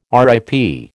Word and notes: RIP: American English

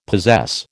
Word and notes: possess: American English

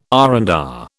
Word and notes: R and R: British English

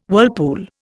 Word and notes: whirlpool: Indian

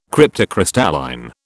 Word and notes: cryptocrystalline: British English

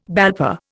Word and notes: BALPA: Indian

